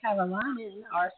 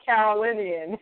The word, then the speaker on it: Carolinian: I'm going to say it wrong. North Carolinian. How do you say it? – North Carolinian. – Carolinian, thank you.